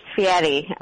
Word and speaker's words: Fieri: Yeah, it's pronounced Fieri, okay.